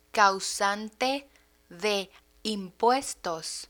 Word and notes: causante de impuestos: spanish

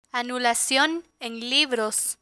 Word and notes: anulacion en libros: spanish

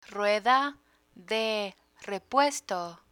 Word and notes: rueda de repuesto: spanish

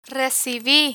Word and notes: recibi: Spanish

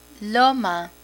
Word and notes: loma: Spanish

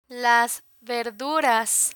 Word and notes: las verduras: Spanish

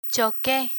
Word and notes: choque: spanish

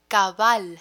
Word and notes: cabal: spanish

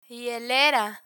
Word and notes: hielera: Spanish

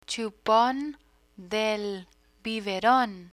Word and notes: chupon del biberon: spanish

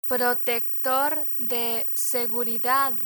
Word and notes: protector de seguridad: Spanish

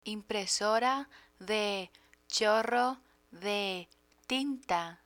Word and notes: impresora de chorro de tinta: Spanish

